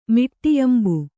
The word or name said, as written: ಮಿಟ್ಟೆಯಂಬು